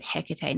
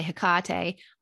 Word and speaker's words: Hecate: I um, you'll probably hear a lot of witches work with Hecate. Now, you can say her name some people say Hecate, I say Hecate.